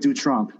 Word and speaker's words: Dutronc: We're going to go with Dutronc. Yeah, that's how they say it in France.